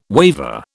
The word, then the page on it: waver: British English